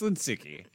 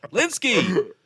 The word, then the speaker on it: Lynskey: Why don't you say it right? It's Lynskey. – Lynskey!